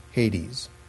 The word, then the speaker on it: Hades: or Hades, or Hades, as I think it is properly pronounced in a Greek term, Hades.